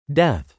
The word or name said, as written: death